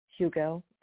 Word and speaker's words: Hugo: It's my name is spelled with an E, L-Y-N-N-E, Hugo, H-U-G-O.